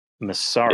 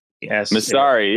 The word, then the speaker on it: Masari: It's spelled M-A-S-A-R-I. Oh, yeah. – Messari. – Yeah, but Messari, yeah.